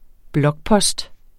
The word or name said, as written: blokpost